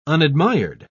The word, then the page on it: unadmired: British English